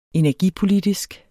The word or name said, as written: energipolitisk